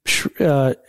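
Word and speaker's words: Shirer: I'm reading right now William Shira. I think that's how you pronounce his name. – Yeah.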